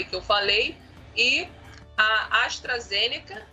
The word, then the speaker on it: AstraZeneca: A pronúncia é AstraZeneca.